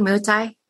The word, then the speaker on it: Muay Thai: Muay Thai? – Yeah, Muay Thai.